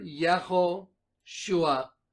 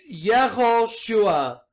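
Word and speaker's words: Yehoshua (יְהוֹשֻׁעַ): Of Hebrew origin, 3091, say it right here, Yehoshua. So let's click on this, watch this. It's Yehoshua.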